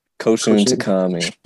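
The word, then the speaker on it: Koushun Takami: We're talking about the 1999 manga and the 2000 film Battle Royale, written by, how you pronounce the dude? Koshun Takami? – Yeah, Koshun. – Koshun Takami.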